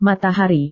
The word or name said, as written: matahari